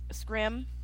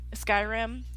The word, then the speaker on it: Skyrim: because I've never played Scrim before. – Mm-hmm. – Skyrim.